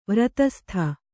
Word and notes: ವ್ರತಸ್ಥ: vratastha

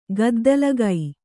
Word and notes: ಗದ್ದಲಗೈ: gaddalagai

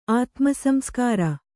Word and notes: ಆತ್ಮಸಂಸ್ಕಾರ: Kannada

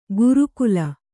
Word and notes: ಗುರುಕುಲ: guru kula; kannada

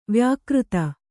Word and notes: ವ್ಯಾಕೃತ: vyākřta

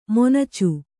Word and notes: ಮೊನಚು: monacu

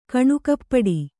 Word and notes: ಕಣ್ಣುಕಪ್ಪಡಿ: kaṇṇukappaḍi

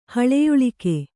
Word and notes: ಹಳೆಯುಳಿಕೆ: haḷeyuḷika

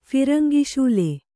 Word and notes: ಫಿರಂಗಿಶೂಲೆ: Kannada

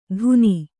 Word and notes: ಧುನಿ: kannada

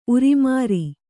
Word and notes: ಉರಿಮಾರಿ: urimāri

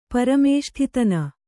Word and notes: ಪರಮೇಷ್ಠಿತನ: Kannada

